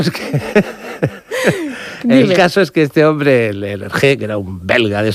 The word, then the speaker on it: Hergé: y utilizó la R de Remy, que allí se pronuncia R, uh-huh, R de Hergé, y el nombre de George G. RG.